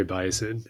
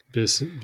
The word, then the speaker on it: Bisson: Terry Bison. Yeah. – Bison. – Bisson. Okay. I mean, it shouldn't be a long I. There's two S's, right?